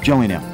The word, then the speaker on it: Gillingham: So it's a soft G? – It's Gillingham.